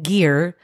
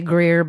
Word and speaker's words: Geer: I totally said her name wrong. It's Sara Ann Geer. – That was G-E-E-R, right? – Yes. Yeah. No Greer. G-E-E-R. And we didn't actually realize that I was saying it wrong until the very end. So the whole time you'll hear me say Greer, but I know it's Geer.